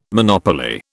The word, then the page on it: monopoly: British English